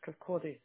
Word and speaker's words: Kirkcaldy: So I'm just going to repeat that back to Kilo Yankee 1, 1 Sierra X-Ray, and it's Leslie Street in Kirkcaldy, is that correct? – Yeah, Kirkcaldy. Kirkcaldy, I do apologise.